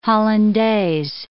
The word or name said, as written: hollandaise